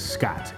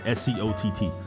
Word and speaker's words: Scott: My name is Scott, S C O T T, Scott, and everywhere I go is Scotch, like Scotch whiskey.